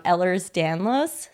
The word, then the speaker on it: Ehlers-Danlos: And Katie, I know that you actually both share this in common, that you both have, correct me if I'm saying it wrong, Ehlers-Danlos syndrome. Say it correctly. Okay, great.